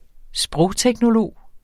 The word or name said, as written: sprogteknolog